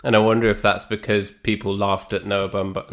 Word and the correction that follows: Baumbach: No, I can't even say his name properly because on my computer it also corrected it to Bumbag. But Noah Baumbach. – Baumbach, yeah. His previous films were called things like The Squid and The Whale, and I think people sniggered at that.